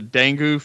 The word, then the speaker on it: dengue: The dengue fever? – Dengue.